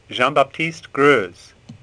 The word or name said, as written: Greuze